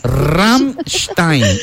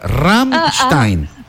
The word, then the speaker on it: Rammstein: Me llega, no, mire, se dice Rammstein. A ver, repita. Rammstein.